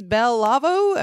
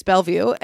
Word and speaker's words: Bellevue: Not Bellevue, because I said, is this Bell Lavo or something? Because it's spelled very French and I Americanized it. I made it very bad. And he was like, no, first off, you're an idiot. It's Bellevue.